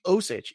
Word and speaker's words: Osich: Osich. Former Giant. – Yeah, I thought it was Osich, but it's hard to say.